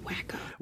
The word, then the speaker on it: Waco: It's pronounced Waco.